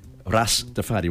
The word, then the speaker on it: Rastafari: Ras Tafari vuol dire il re, il capo Tafari, che i giamaicani pronunciano Rastafari, lo pronunciano in questo modo, ed era il loro punto di riferimento, il punto di riferimento della terra promessa.